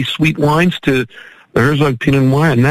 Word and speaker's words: Pinot Noir: Is that the right pronunciation? Pinot Noir? – Yes.